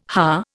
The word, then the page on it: Ha: Indian